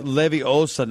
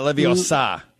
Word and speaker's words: Leviosa: Remember, it's Leviosa, not Leviosa. – Leviosa, not Leviosa. Right? – Got to put the emphasis on the second syllable.